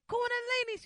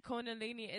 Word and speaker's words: Kundalini: Cause I was in it saying it wrong. He was like, "Nah, Tab put me on blast, y'all." So I'm in the crowd. The crowd get low. I was like, "Kundalini." She was like, "Nah, son, that's not it." I'm like, "You saying it right now? Well, the way you said it earlier wasn't right, but you got it right now."